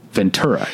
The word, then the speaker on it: Ventura: But once you've lived here, you know Ventura. It's a hard T.